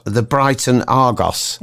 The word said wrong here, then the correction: Argus: Sorry, I've mispronounced it. The Brighton Argus.